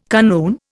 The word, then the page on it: kanoon: Indian